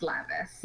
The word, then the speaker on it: Lavias: I just changed the spelling part way through. It's always been pronounced Lavias.